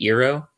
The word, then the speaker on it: Iroh: I'm sure it's a lot Iroh vibes. Fun. Yep. I think you mean Iroh. – Sorry. I'll pronounce it how they did it in the movie. – Like, it's the Greek pronunciation, the true pronunciation.